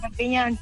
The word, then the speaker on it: Perpiñán: Lo he dicho bien, Perpiñán, ¿no? – ¿Se puede? Perfecto, Perpiñán, sí.